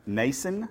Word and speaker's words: Mnason: Next week, we've got Nason. That's how you say his name. M-N-A-S-O-N.